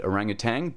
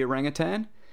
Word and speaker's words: orangutan: Uh, I mean, you wouldn't be incorrect in saying that because when the orangutan was first described, they did include that G on the end, orangutang, because it was mispronounced uh, when they were describing the orangutan. Uh, but still, we know better now. So it's orangutan rather than orangutang. Every time I hear people say, I've heard uh, people that um, actually work with orangutans saying it orangutang, and I'm like, oh come on, it's not how you say it, man.